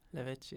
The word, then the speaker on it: Lavezzi: Lavezzi. – Lavezzi, ja.